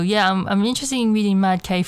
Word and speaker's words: MADK: When she pronounced it, it's pronounced Mad K. I didn't know that. I used to say MADK, so I've been pronouncing it wrong, because I was so confused how you pronounce this name. MADK, but I call it MADK because I thought it was an abbreviation of abbreviation thing, but she called it Mad K.